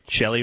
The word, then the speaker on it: Chely: I don't know how to pronounce this name. C-H-E-L-Y, Wright. She's a woman. – Shelley Wright. Yeah.